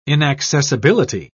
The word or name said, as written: inaccessibility